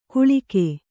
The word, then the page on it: ಗುಳಿಗೆ: guḷige